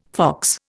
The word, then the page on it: fox: Indian